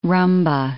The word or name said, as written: rumba